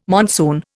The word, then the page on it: monsoon: Indian